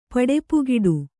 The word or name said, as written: ಪಡಪುಗೆಡು